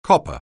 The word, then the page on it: copper: anglais